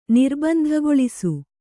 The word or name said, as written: ನಿರ್ಬಂಧಗೊಳಿಸು